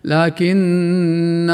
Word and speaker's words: لكن: أما الألف الثانية والتي لا تلفظ وصلا كما قلنا، ففي كلمة لكن في قوله سبحانه في سورة الكهف لكن هو الله ربي، وهي الوحيدة في القرآن الكريم.